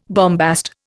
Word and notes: bombast: Indian

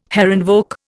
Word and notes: Herrenvolk: Indian

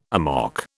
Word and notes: amok: British English

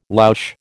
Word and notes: louche: American English

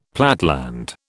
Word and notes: platteland: British English

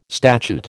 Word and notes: statute: American English

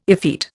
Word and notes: effete: Indian

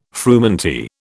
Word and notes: frumenty: British English

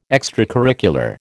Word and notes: extracurricular: American English

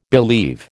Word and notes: believe: American English

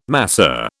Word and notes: masseur: British English

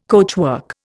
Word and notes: coachwork: Indian

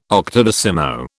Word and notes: octodecimo: British English

